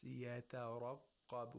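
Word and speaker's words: يترقب: When we want to pronounce يَتَرَقَّبَ, we pronounce it with caution. We pronounce it cautiously. Why? Because we have two letters of تَرْقِيق, one letter of ra with tafkhim, and then a qaf with tashdeed, with shatta and tafkhim. We have to, we have to pronounce it deliberately, with caution.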